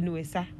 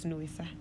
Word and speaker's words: Nuesa: So the bully people call it Nuesa. – Nuesa. – Yeah, that means Footwear. – So three syllable. Nuesa. – Yes, Nuesa.